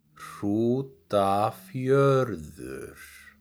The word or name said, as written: Hrútafjörður